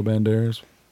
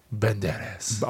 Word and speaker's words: Banderas: With Antonio Banderas. – You said it wrong. – Banderas.